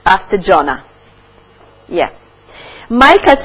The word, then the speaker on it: Micah: So we have one reference, Bible reference for that in Micah. Is it Micah pronunciation? Yeah. Micah chapter 6, verse 8.